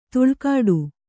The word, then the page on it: ತುಳ್ಕಾಡು: tuḷkāḍu